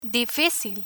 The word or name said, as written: dificil